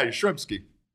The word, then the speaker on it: Yastrzemski: Yet all baseball fans look at it and go, "Yeah, Yastrzemski."